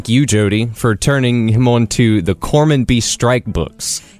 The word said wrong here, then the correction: Cormoran: Or I don't think I said that right. – Cormoran. – Cormoran. – I think that's Cormoran. Okay, I don't know what to call it.